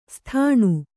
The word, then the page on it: ಸಾದಣ: sādaṇa